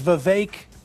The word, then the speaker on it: Vivek: I've pronounced the name correctly from the get-go. – Right. – But people are confused sometimes by it because of the spelling Vivek. You get a lot. It is Vivek like cake.